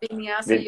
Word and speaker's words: Vinyasa: Vinyasa yoga. – Vinyasa. Okay. Vinyasa. Yep. – Yeah. I mean, the benefits is you're connecting with the breath. Yoga, you're connecting with the breath. Vinyasa, you're moving with the breath.